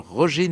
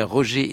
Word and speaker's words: Roger: Oui, je dis bien Roger et non Roger, car Norrington, un jeune chef d'orchestre dynamique et joyeux de 82 ans, tient à ce que l'on prononce son prénom à la française.